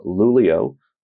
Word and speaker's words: Luleå: So Volvo invited us up to Luleå, spelled L-U-L-E-A, but that is the way that you pronounce it up there in Sweden, which is pretty fun to say, actually, going to Luleå.